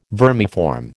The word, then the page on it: vermiform: American English